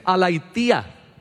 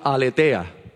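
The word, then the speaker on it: Aletheia: Pero esa palabra es una palabra que hasta en la ah, literatura griega y en la mitología griega se ve, es Alaitía. Alaitía, hay una diosa griega con ese nombre. Lo que pasa es que nosotros diríamos Aletea aletea, pero se pronuncia alaitía.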